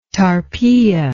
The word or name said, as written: Tarpeia